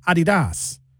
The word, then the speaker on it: Adidas: In fact, we're saying it wrong. The Germans say Adidas. That's how they say it. It's German.